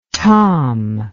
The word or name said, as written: tamm